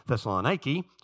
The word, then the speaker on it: Thessaloniki: 2 Thessalonians was written because 1 Thessalonians, the first letter to the people in Thessaloniki, and that's what a Greek woman told me was the proper pronunciation of that city, Thessaloniki, so that's what I'm going to say.